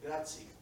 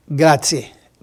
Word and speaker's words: grazie: How do you say, I know more or less, but I don't want to butcher language, so say gracias in Italian. – Grazie. – Grazie. Grazie mille.